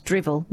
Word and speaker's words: Drival: But Drival in Scottish is pronounced drivel, means spoken nonsense.